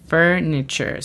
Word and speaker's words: furniture: La pronunciación fonética es L-E-V-O-L. – Level. – Muy bien. A continuación tenemos furniture. Furniture. ¿Sabes qué significa furniture? – Son muebles. – Yes, muebles. La pronunciación fonética es F-R-N-E-C-H-R. Nota que casi no tenemos vocales.